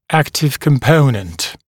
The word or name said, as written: active component